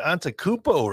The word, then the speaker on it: Antetokounmpo: It's like, I got Antetokounmpo, right?